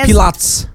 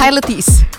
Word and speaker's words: Pilates: Pilots. – Bacanya apa? – Pilates.